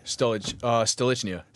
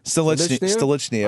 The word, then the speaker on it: Stolichnaya: How do you pronounce Stoli the correct way? – Stolichnaya. – Stolichnaya. – I almost said it like you.